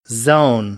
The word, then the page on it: zone: American English